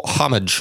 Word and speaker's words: homage: Homage. How do you say that? – Homage. – Homage. – Homage. Yeah. And at this point, Santa.